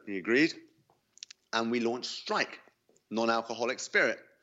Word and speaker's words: STRYYK: Yeah, so first of all, it's spelled, just for information's sake, STRYYK, Strike.